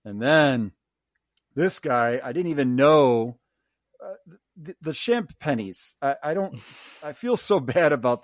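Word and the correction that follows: Champagnie: So Champagne is how you say their name.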